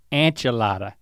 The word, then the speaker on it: enchilada: Enchilada. It's an E, not an you I.